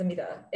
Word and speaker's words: Amita: So when I introduce myself, I say, hi, my name is Amita. It rhymes with fajita. That way you don't forget it.